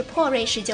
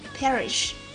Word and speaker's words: perish: 瑞士是生活的地方，但是破瑞士就不一样了。一个国家破了就会毁灭、消失。这个单词不要读成破瑞士，跟我来读 perish, perish, 毁灭、消失、腐烂。